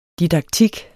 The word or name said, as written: didaktik